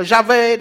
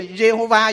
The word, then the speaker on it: Yahweh: Nó không có cái nguyên âm, cho nên mình đọc là Yahweh, đọc là Jehovah vậy đó.